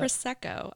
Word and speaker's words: Prosecco: Prosecco.